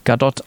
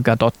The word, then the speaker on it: Gadot: Da haben sie die gefragt, wie ihr Name eigentlich ausgesprochen wird. Und die wird Gadot ausgesprochen, weil die aus Israel kommt. Für alle anderen Podcasts auch. Für euch. Also, Gal Gadot aus Gal Israel.